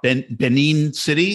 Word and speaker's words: Benin City: It's very interesting because reading about, I don't know a lot about Benin City. Is that how to pronounce it? – Yeah. Yeah.